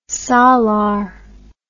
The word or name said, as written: salar